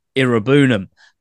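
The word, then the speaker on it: Iroegbunam: The lovable rogue nickname that doesn't really work now that I've been educated on how to actually say his name. It's Tim Irabunam.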